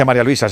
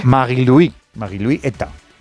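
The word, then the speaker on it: Marie-Louise Eta: No se dice María Luisa, se dice en su... Sí. Magui-Louis Eta.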